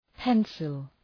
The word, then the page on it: pencil: ‘pensəl